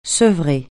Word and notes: sevrer: francais

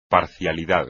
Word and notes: parcialidad: espagnol

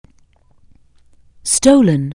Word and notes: steal: anglais

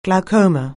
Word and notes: glaucoma: anglais

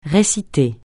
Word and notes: réciter: francais

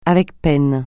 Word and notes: avec peine: francais

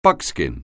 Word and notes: buckskin: anglais

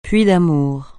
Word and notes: puits d'amour: francais

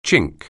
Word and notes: chink: anglais

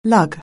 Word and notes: lug: anglais

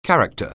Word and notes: character: anglais

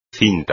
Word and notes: cinta: espagnol

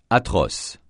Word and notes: atroce: francais

